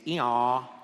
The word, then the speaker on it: Eeyore: in English, in the R isn't pronounced, so it's basically the sound that a donkey makes, eeyaw, eeyaw, like that.